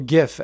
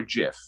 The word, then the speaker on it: GIF: Is it GIF or JIF? – It's JIF. The inventor of it says it's JIF. So all you people, sorry. – What are you bringing as a riot to that? – The inventor of the format says that it's pronounced JIF.